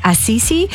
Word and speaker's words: Assisi: Assisi, patron saint of animals.